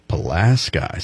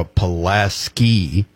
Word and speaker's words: Pulaski: Pulaski. So we have a Pulaski. It's spelled the exact same way, but we say Pulaski County here in Kentucky.